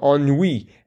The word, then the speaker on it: ennui: So it's pronounced, at least I would say, you know, lapsing into fake French, the royal ennui, as in the royal we. – Oh. – But it's the royal ennui. – Oh. – So, and of course, ennui being bored.